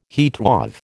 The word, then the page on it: heatwave: American English